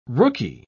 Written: rookie

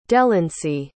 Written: delancy

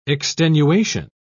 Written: extenuation

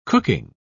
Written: cooking

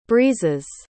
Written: breeze's

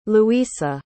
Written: Luisa

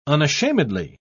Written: unashamedly

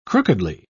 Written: crookedly

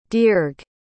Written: dearg

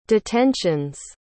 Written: detentions

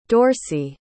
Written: Dorcey

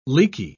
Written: leaky

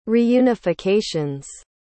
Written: reunifications

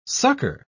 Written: sucker

